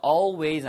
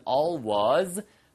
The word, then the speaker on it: always: always 아니면 always 두 가지 발음 다 좋고요.